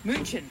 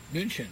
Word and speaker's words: München: P-A-U-L-A-N-E-R. Paulaner. This is another Munich beer. – München. – München, yeah.